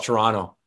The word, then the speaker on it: Toronto: And yeah, I was born here in Toronto, and it's funny, here in Toronto, We don't like pronouncing T's when they're in the middle of words. So we just call it Toronto. It just kind of rolls off the tongue, and Toronto.